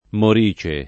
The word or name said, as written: morice